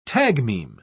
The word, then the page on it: tagmeme: British English